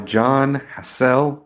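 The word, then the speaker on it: Jon Hassell: And it is Fourth World, Volume 1, Possible Music by John Hassell.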